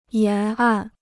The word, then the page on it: 沿岸: yán àn